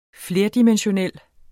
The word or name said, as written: flerdimensional